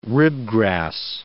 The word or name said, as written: ribgrass